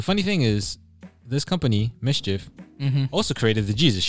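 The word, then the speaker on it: MSCHF: Mischief. Well, that's how it's pronounced. – My fault. Mischief.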